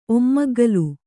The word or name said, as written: ಒಮ್ಮಗ್ಗುಲು